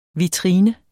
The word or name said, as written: vitrine